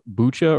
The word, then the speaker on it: Bucha: There's been, especially in a village called Bucha or Buka, I've heard it pronounced both ways, we have some really shocking images.